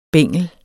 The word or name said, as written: bengel